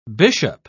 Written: bishop

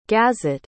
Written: gazit

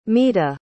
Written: mita

